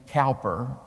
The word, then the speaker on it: Cowper: Now, Cowper is spelled C-O-W-P-E-R. But if you say Cowper in England, they'll laugh at you. It's William Cowper.